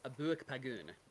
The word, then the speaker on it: Abooik Paagun: It was Abooik Paagun.